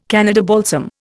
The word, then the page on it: Canada balsam: Indian